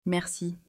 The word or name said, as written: Merci